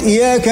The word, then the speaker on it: إياك: إياك نعبد بطلت صلاتك لأنه الشدة حرف من حروف القرآن الشدة إياك يعني الياء مرتين لازم تلفظها إي هيا بعدين ياك إي يا